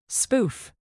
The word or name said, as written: spoof